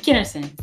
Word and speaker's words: Kennerson: How you pronounce your last name, Kevin? I don't want to pronounce it wrong. – Kennerson.